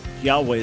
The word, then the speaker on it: Yahweh: But if we're trying to say this is how it was originally pronounced in Hebrew, then yes, it would have been Yahweh as best as we understand.